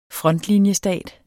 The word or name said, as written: frontlinjestat